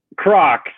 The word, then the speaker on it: Croc: Croc. So if you think of it like a crock pot, that's the best way I can use to describe it. Then you're usually going to say it right every time.